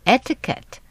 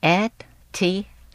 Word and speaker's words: etiquette: E-T-I-Q-U-E-T-T-E. – Oh, etiquette. 原来是法文，意思是礼节。 – 哦，原来是让我去参加礼节训练班啊。哎 这个词是怎么发音的？ – Etiquette. 那个 Q-U 啊，是发 K 的音，而不是发 Q 的音。 Etiquette.